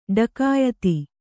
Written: ಢಕಾಯಿತಿ

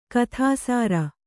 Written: ಗೋಧಿಸರ

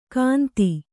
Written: ಗಂತಿ

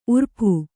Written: ಊರಪ್ಪ

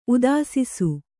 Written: ಉದಾಸಿಸು

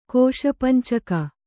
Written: ಕೋಶಪಂಚಕ

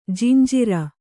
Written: ಜಂಝಾರ

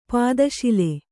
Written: ಪೀತಸಾಲ

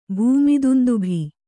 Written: ಭೂಮಿದುಂದುಭಿ

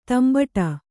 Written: ದಂಬಡಿ

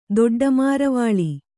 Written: ದೊಡ್ಡಮಾರವಾಳಿ

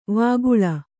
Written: ವಕೀಲಿ

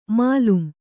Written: ಮೇಲ್ಮೈ

